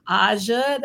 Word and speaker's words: Aja: Once again, my name is Aja McNair, and you can find me on all social media platforms at Everything Aja.